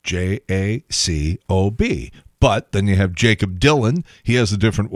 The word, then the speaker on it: Jakub: And for whatever reason, they decided when he was born to spell his name J-A-K-U-B instead of J-A-K-O-B. But it's still pronounced Jacob, right?